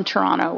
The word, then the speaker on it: Toronto: I pronounced it not like someone from Toronto would say it, though. – Yeah, you're supposed to drop the second T, right? – Yeah, normally I would say Toronto, Canada.